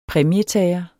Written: præmietager

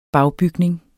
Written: bagbygning

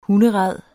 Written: hunderæd

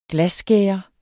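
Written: GLASSKÆRER

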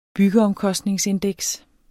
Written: byggeomkostningsindeks

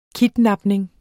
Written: KIDNAPNING

